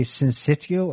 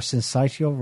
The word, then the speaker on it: syncytial: RSV, respiratory, how do you pronounce that? Respiratory syncytial or syncytial virus.